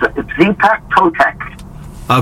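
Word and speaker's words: Xipe Totec: And then the next one, I'm going to try to pronounce this best I can, track 6. Zipe Totec? – Yeah, it's Zipec Totec. – Okay, Zipec Totec.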